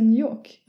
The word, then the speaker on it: New York: Ja, man ska säga New York. – Ja, utan att uttala R-et.